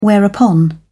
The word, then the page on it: whereupon: British English